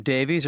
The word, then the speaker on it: Davies: Now, does he pronounce his name Davies or Davis? – Davies, I think. – Like Ray Davies is yeah. Ray Davis. – Right. There. But yeah, no. But his is IES.